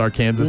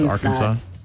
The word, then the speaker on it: Arkansas: Arkansas is not pronounced Arkansas. Mm-hmm, it's Arkansas.